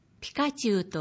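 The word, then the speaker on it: 光宙: 横書き漢字で、光と宇宙の宙の二文字です。ピカチュウと読み仮名が記されています。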